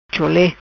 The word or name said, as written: Chole